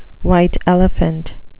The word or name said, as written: white elephant